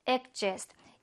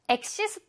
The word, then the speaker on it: exist: Exist 얘가 이제 발음을 할때 약간 G 발음, 그러니까 그 발음이 들어간 거라고 생각하면 돼, 이렇게. 발음에서는. 그래서, exist 아니야, 얘들아. 그니까, 그 발음이 명확하게 나진 않아. 그치만, 있다고 생각해 주시고, exist. 한번 더, exist라고 발음을 합니다.